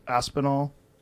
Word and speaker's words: Aspinall: I think it's pronounced Aspinall. He's an English gentleman. – That's right.